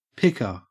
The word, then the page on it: picker: Australian English